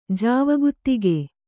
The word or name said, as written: ಜಾವಗುತ್ತಿಗೆ